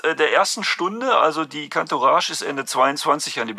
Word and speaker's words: Cantourage: Da ist uns aufgefallen, Cantourage, wie spricht man das denn aus? – Cantourage. – Französisch.